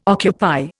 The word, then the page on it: occupy: Indian